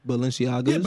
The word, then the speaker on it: Balenciaga: Balenciaga.